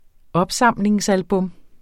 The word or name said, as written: opsamlingsalbum